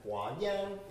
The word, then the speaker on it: Huayan: And Huayan is how you say flower garland in Chinese.